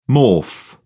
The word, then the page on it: morph: British English